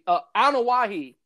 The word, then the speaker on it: Anoa'i: Anoa'i, that's how you pronounce it.